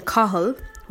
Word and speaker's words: Cathal: So Irish boys' names, some of the correct pronunciations for them would be, for example, Donica, which is D-O-N-N-A-C-H-A. And then Cahal, which is C-A-T-H-A-L.